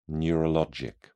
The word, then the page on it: neurologic: British English